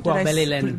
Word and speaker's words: Poire Belle Hélène: Poire Belle Hélène.